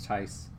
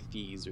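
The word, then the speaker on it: Theis: So today he got denied by, how do you say his name? Tyes? Daniel Tyes? I always mispronounce it. Yeah, I think it's Tyes. – Tyes. I always want to say Thies or something.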